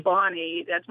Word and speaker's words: Bonney: And we better point out that my middle name, Bonnie, happens to be my maiden name, but it's spelled B-O-N-N-E-Y, not I-E.